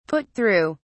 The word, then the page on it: put through: British English